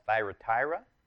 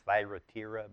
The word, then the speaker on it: Thyatira: Thyatira, it's actually better pronounced Thyatira, but either way, church at Thyatira remains today in the sense of the people that Jesus is talking about.